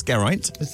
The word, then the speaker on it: Geraint: Geraint. It is Geraint. – That's right. – Yeah, it's Welsh.